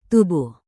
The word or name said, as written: tubuh